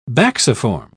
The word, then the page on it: bacciform: British English